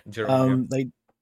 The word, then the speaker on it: Girona: I think it's Girona or Girona. – Girona, yeah.